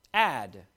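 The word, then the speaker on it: Ad (עד): The word everlasting, Ad, as it would be pronounced.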